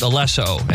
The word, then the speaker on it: Alesso: It actually pronounced Alesso, and he's just the guy makes the beats.